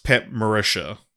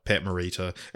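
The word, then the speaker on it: Pat Morita: Well, I, I would always heard it pronounced Pat Marisha, right? Um, M O R I T A, but in the community episode he says uh, Pat Marita, and his his first name is Norioki, but he Noriyuki, sorry.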